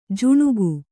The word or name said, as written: ಜುಣುಗು